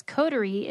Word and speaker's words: Coterie: That's Coterie. It's spelled C-O-T.